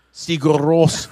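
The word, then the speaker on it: Sigur Rós: And then Sugar Rose. – Sigur Rose.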